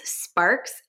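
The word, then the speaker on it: Sparkes: So people can find me just at my name, which is Elise Sparks. And it's a little tricky because there's a silent E at the end of the spark.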